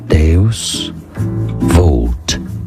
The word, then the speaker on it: Deus Vult: How do you pronounce this Latin phrase? In English, it's pronounced as Deus Vult, a Christian motto relating to divine providence, meaning God wills it.